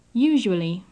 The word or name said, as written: usually